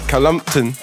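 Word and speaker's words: Cullompton: Kane Shuttler from Columpton. – Is that pronounced correctly? – Yeah, it's exactly that. Columpton. That's how they say it out there.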